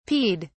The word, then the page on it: peed: British English